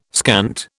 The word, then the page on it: scant: British English